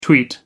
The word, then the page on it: tweet: American English